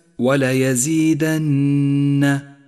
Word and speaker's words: ولا يزيدن: رابعا، لاحظ النون المشددة في كلمة ولا يزيدن ولا يزيدن، لونت باللون الأخضر لأنها تحتاج إلى غنة زمنها حركتان.